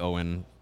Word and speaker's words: Owyn: It is not spelled the same way. It is actually spelled O-W-Y-N.